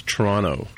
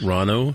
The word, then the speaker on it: Toronto: Well, it's not Toronto. Toronto, it's Toronto. – Toronto. – We kind of roll over the last T, right? – Oh, okay. – Like Rono. – Toronto.